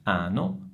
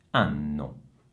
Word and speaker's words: anno: E allora non è ano, ma è anno. Ok? Dovete prolungare questo suono, non in modo così esagerato, ma basta dire anno, anno.